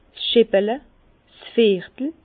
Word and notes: 's Scheppele: Bas-Rhin; Herrlisheim

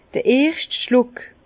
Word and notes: d'r erscht Schluck: Bas-Rhin; Herrlisheim

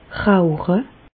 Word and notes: rauche: Bas-Rhin; Reichshoffen

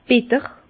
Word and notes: bitter: Bas-Rhin; Herrlisheim

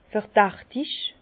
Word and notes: verdächtig: Bas-Rhin; Herrlisheim

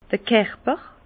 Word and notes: d'r Kerper: Bas Rhin; Herrlisheim

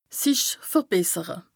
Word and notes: sich verbessere: Bas-Rhin; Strasbourg

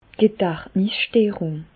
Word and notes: d' Gedächtnisssterùng: Alsatian; Bas-Rhin; Reichshoffen

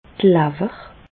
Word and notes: d'r Lawer: Bas-Rhin; Reichshoffen

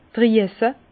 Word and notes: d' Drüesse: Bas-Rhin; Herrlisheim

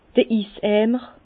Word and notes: d'r Iseimer: Bas-Rhin; Herrlisheim

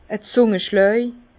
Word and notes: e Zungaschlaj: Bas Rhin; Herrlisheim

